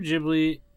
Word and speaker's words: Ghibli: It's technically pronounced Ghibli, but everyone calls it something different.